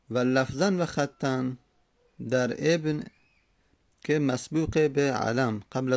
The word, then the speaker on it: ابن: همزه وصل که گفتیم در هنگام تلفظ زدن، در هنگام تلفظ زدن اگر اول کلام اومد همزه باقی میمونه، میگیم ابن. اول کلام میگیم ابن، همزه رو تلفظ میزنیم. در وسط کلام همزه وصل میفته، مثلا و بیاریم قبل از اون میگیم وابن، وابن.